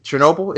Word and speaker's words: Chernobyl: Chernobyl, is it? – Yeah.